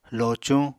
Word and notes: locuñ: Apatani

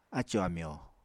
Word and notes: acò-amyò: Apatani

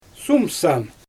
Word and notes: sumsam: Xyzyl

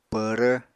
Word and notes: pvrv: apatani

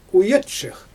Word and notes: ujatʃɯx: Xyzyl

